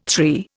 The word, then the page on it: three: Indian